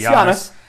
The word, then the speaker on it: Giannis: Giannis. – Giannis or whatever. Yeah, it's Giannis.